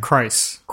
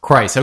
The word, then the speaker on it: Kreis: Christ. Christ.